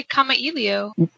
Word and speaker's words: Kamailio: How do you pronounce Kamailio world? – I just say Kamailio.